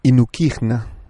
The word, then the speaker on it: INUKIJNA: Esta, esta palabra es inukigna, si uno quiere pronunciarla. I-N-U-K-I-J-N-A. Inukigna.